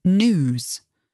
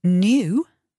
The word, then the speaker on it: new: So new is spelt N-E-W, and news is spelt N-E-W-S. That's me speaking, so it's the British way. But the American pronunciation is more as though it's spelt N-O-O or N-O-O-S, new or news. And the British pronunciation, well, it's as though there is a letter Y in there, new or news. So as though it's spelt N-Y-O-O or N-Y-O-O-S.